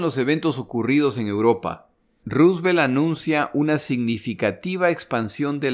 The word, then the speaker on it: Roosevelt: En 1940, cuando los alemanes dejan claro que planean invadir Gran Bretaña, el presidente Roosevelt, a propósito, ya me corrigieron la pronunciación de este nombre. Se debería decir Roosevelt, no Roosevelt pero el nombre de este señor ha sido maltratado demasiadas veces hasta este punto para cambiar.